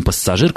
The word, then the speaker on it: пассажир: Хуже, когда люди пытаются растягивать согласные, вот как со словом «пассажир», который на моих глазах, просто на наших глазах, явно начинают произносить побуквенно, что делать ни в коем случае, конечно, не нужно.